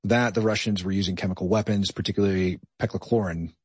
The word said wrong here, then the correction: chloropicrin: Did I pronounce that right? – Chloropicrin.